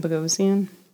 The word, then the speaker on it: Bogosian: How do you say that? – Where's his name? Bogosian? – Bogosian.